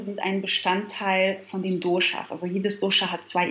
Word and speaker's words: Doshas: Ich habe mich ein bisschen schlau gemacht, selbstverständlich, und da bin ich auf die drei Doshas gekommen. Ich hoffe, ich habe es richtig ausgesprochen. – Ähm, Doshas. – Doshas, okay, alles klar.